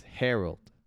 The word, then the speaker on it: herald: So it's Harold.